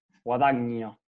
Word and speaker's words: Guadagnino: Yo vengo a enseñarles se pronuncia, la tilde se pone como si fuese una palabra grave y se pronuncia guadagno.